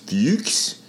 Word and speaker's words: Fuchs: And the person chairing my panel, his name is Dr. Rolf, and I guess in German it's pronounced Fuchs, but it's spelled F-U-C-K-S. So his name is Fuchs.